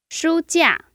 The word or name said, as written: shu1jia4